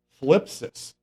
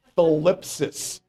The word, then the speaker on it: thlipsis: The Greek word is, this is hard to pronounce, thlipsis. T-H, thlipsis. You know why they say it's Greek to me? Because English to Greek doesn't work that great. I had to wet my whistle there before I say it right. Thlipsis, okay? T-H-L-I-P-S-I-S, all right?